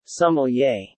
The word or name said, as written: sommelier